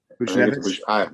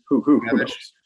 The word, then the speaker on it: Buchnevich: That's Buchnevich. How you say it. Buchnevich. – Buchnevich. – Let's go Buchnevich. – That sounds right. – Actually sounds more right.